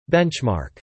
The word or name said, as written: benchmark